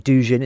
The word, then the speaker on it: Dugin: And just since I mentioned conspiracy theories, listeners will recall the name of Alexander Dugin, or Dugin as it's sometimes pronounced, the so-called Russian intellectual who's believed to be the target of a Ukrainian assassination plot which killed his daughter in 2022.